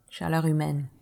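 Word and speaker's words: Chaleur humaine: Chaleur humain. – Chaleur humain.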